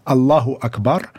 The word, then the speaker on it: Allahu Akbar: je vais le prononcer vraiment en bon arabe parce que je parle très bien l'arabe et je le maîtrise, Allahu Akbar, ça, moi, j'ai honte personnellement.